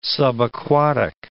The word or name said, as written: subaquatic